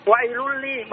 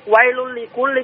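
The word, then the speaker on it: wailul likulli (وَيْلٌ لِكُلِّ): Orang sering baca itu nah apa surat Al-Quran ya bilangnya wa'iluli. Bagaimana Pak Ustaz? Bagaimana bacanya? Orang sering baca wa'iluli kulli humasati. Dan kan di situ wailul likulli, tapi kebanyakan di sini baca wailul likulli, bagaimana bahasa yang benarnya?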